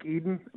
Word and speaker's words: Edun: It's called Lake Eden, but it's spelled E-D-U-N, and I go there, and they got a good-sized lake, I mean, you know, for being what it is.